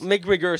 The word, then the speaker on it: McGregor: McGregor, c'est ça.